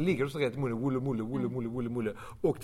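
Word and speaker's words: Woolloomooloo: Det är alltså Woolloomoolloo. Woolloomoolloo.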